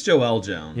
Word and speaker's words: Joelle Jones: Now, the big bad in this whole Jolyel Jones story, I don't know if I'm saying her name right, Joelle Jones. – I think it's Joelle Jones.